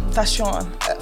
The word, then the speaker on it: Faseun: Faseun.